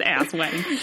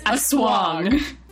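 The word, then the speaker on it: aswang: Julia, it's not pronounced ass wang. – It's a swong.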